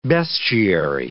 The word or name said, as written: bestiary